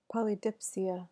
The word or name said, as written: polydipsia